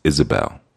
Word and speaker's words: Jezebel: So there was no J. It would be a Y or even an I, most likely. Sometimes even a G sort of sound. But really the name would be pronounced Isabel, you know, or Isabel or Jezebel, but primarily like Isabel.